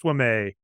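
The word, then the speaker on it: Ouimet: Most Boston thing ever, because it is a French name that is pronounced Wamet, and they're like, no, we're not doing that.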